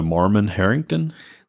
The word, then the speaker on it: Marmon-Herrington: The Marmon Herrington.